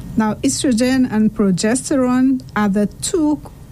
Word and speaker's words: estrogen: Changes in estrogen or estrogen, some persons pronounce it that way, progesterone.